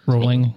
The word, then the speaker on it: Rowling: Did I say that right? Rollins? – Rolling. – Rolling? – Bowling with an R.